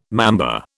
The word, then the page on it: mamba: British English